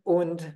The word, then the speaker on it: und: Yeah, make sure you pronounce it as und and not as and, like it would be in English. It's slightly different.